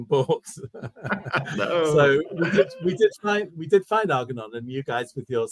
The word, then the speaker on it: Argonon: What's the backstory of Argonon, and how do you pronounce it properly so that we got it down? – Argonon.